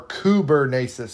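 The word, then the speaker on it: kubernesis: Or Kubernasis, sorry. Kubernasis is how you pronounce it. It's spelled in the in Greek, obviously. K U B E R N E S I S. K U B E R N E S I S. Pronounced Kubernasis. Kubernasis.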